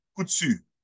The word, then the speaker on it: Coutu: Samuel Kachu.